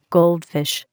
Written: goldfish

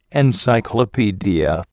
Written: encyclopedia

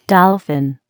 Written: dolphin

